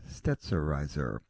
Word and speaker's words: Stetzerizer: It's STETZ, STETZ, STETZ, and then ERIZER, and the name pronounced Stetzerizer.